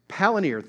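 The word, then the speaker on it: Palantír: Palinir? Palinir, thank you.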